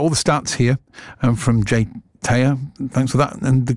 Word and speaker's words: JTAYH: It's written as, I'll say this because I'll stop James doing it, but J-T-A-Y-H, but pronounced Jay Taya.